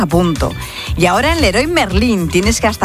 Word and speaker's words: Leroy Merlin: Mensajito, ¿cómo se dice? ¿Leroy Merlin o Leo Guamerala? – Leroy Merlin. – Amigas, ¿son franceses? – No, no, Leroy Merlin. Merlin, Leroy Merlin, toda la vida.